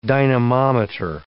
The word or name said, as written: dynamometer